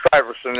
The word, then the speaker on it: Stuyvesant: Stevenson, or Stuyvesant, is that how you pronounce it? Stuyvesant, isn't it? – Right, right.